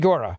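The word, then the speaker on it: Gura: David Gora, is that his name? Am I saying that right, David? – Gora. Up with David Gora.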